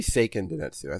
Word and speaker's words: Seiken Densetsu: It should be Seiken Densetsu.